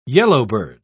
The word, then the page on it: yellowbird: British English